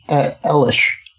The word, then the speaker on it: Eilish: How do you say her last name? – Billie Eilish. – Eilish. Okay.